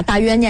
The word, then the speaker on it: 大约: 好的，首先跟大家道个歉，刚才把大约念成大碗了，感谢网友在评论区的指正啊，这个学习到了。